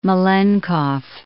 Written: Malenkov

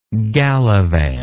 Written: galavant

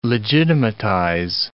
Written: legitimatize

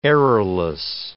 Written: errorless

